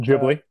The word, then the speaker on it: Ghibli: Studio uh, Ghibli, Ghibli, I don't know how you pronounce it. – Uh, Ghibli, uh, uh-huh.